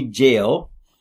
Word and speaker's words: Gaol: And I'll just list very, very quickly for you that Derby Jail, is spelled G-A-O-L.